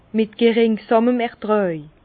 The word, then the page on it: mit geringsàmen Ertràj: Bas-Rhin; Herrlisheim